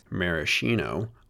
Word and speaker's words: Maraschino: And yes, as an American, I grew up pronouncing Maraschino as Maraschino, but I'll strive to use the Italian pronunciation for this episode.